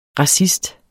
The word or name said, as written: racist